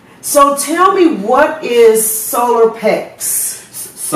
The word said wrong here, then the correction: Soul of Hex: Did I say it right? – No, Soul of Hex. – Soul of Hex. Get it right.